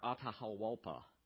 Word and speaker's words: Atahualpa: And the day that Pizarro met the emperor of the Incan Empire, Atahualpa, yeah, that's his name, and that's a correct pronunciation. I can't believe I got it out, Atahualpa.